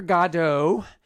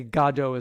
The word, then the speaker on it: Godot: And they always say Godot is like the British pronunciation, but I never saw this angle on it. It's Godot.